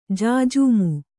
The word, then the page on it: ಜಾಜಮು: jājamu; Kannada